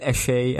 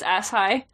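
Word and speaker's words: Asshai: Roy pronounced it as Ashay at this point. I noted that. – Ooh, we don't like I always thought it was Ashai.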